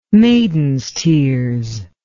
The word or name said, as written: maiden's tears